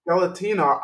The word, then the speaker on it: Skeletina: Tell the, and so Skeletica, did I pronounce that correctly? – Skeletina.